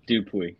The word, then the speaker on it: Dupuis: Dupuis.